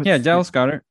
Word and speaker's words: Dallas Goedert: No, it's not Goddard. How do you pronounce? – Dallas Goedert. – Dallas Goedert. Okay.